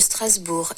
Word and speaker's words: Strasbourg: Racing Club de Strasbourg, RCS.